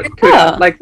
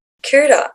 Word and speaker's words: Kuhta: So Kuhta, Barracuda. – Kuhta.